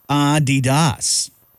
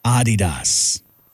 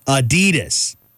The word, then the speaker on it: Adidas: Or Americans, this fun fact is that Americans pronounce Adidas wrong. It's actually supposed to be Adidas with a slight emphasis on the first syllable. So say it with me, Adidas. There you go. Not Adidas, like we all pretty much say.